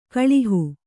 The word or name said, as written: ಕೊಳಹೆ